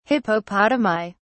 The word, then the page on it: hippopotami: British English